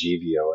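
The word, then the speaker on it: Živio: You asked me earlier, Katie, what is Zivio or Zivio? It's actually pronounced Zivio, and it's my family's Croatian.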